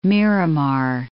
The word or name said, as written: Miramar